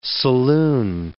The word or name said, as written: saloon